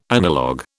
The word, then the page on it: analog: British English